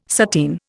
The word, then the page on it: sateen: Indian